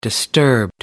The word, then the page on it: disturbed: American English